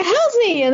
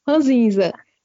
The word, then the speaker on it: ranzinza: Pois é, eu nem fiz perguntas, mas acredito que seja a minha avó mesmo, porque a avó é, é ranzinha, né? Como é que fala? Ranzinza.